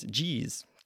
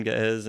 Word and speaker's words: Ge'ez: So it's like G-E hyphen or apostrophe E-Z. And mm-hmm. it's funny because if you look up online how to pronounce G-E-E-Z, then it's G's. But yeah, Ge'ez.